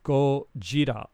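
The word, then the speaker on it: Gojira (ゴジラ): So in Japanese, Godzilla is pronounced Gojira.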